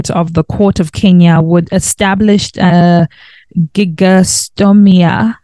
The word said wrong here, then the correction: gigantomastia: Gigantomastia Foundation.